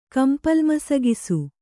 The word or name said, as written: ಕಂಪಲ್ಮಸಗಿಸು